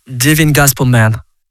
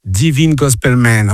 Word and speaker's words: Divine Gospel Men: Comment vous dites Divine Gospel Men ou Divin Gospel Men? Comment on dit? – Divine Gospel Man. – Divine Gospel Man, ok. Un peu avec l'accent.